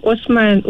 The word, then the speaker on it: Osman: Siti Hasmah Osman juga. – Siti. Macam mana ejaan tu? Asma A-S ke ni? – H-A-S-M-A Hasmah Osman, O-S-M-A-N, Siti Hasmah Osman.